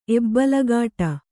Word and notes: ಎಬ್ಬಲಗಾಟ: kannada

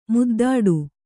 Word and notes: ಮುದ್ದಾಡು: muddāḍu; Kannada

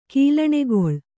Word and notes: ಕೀಲಣೆಗೊಳ್: Kannada

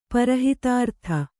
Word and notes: ಪರಹಿತಾರ್ಥ: para hitārtha

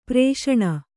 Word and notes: ಪ್ರೇಷಣ: Kannada; prēṣaṇa

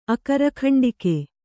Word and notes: ಅಕರಖಂಡಿಕೆ: Kannada

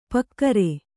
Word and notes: ಪೂಗಾರ: Kannada

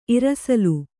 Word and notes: ಇರಸಲು: irasalu

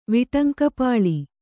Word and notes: ವಿಟಂಕಪಾಳಿ: Kannada

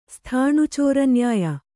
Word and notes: ಸ್ಥಾಣುಚೋರನ್ಯಾಯ: sthāṇu cōra nyāya

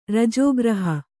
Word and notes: ರಜೋಗ್ರಹ: rajō graha

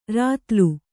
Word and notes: ರತಲು: ratalu

